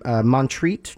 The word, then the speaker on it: Montreat: And he passed away in Montreat, North Carolina. Is that how you spell it? – I love Montreat. – M-O-N-T-R-E-A-T.